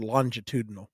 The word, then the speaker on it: longitudinal: Longitudinal. That's a j sound.